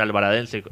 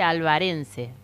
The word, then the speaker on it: alvarense: Alvaradense en contra de otro. – No, no se dice Alvaradense, se dice alvarense.